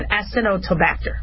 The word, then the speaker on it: Acinetobacter: It's not supposed to be Actinobacter, but Acinetobacter.